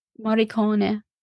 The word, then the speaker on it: Morricone: Kone, it's oh. Morricone. And roll the R's. Imagine the R's rolled.